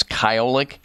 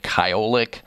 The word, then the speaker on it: Kyolic: I want people to know also that what Dr. Lavelle and I are talking about is Kyolic Aged Garlic Extract.